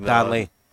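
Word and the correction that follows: Danlí: Danley. Sorry.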